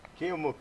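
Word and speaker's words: Keomuka: Am I saying his name right? Duke Kiyomuka. – Kiyomuka, yeah.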